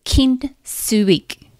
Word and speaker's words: kintsugi: It is called the art, it is the Japanese art of kintsugi. Kinsuik. Let me spell it for you K I N T S U G I. Now, kin means golden and sugi means rejoining. So, kint sugi.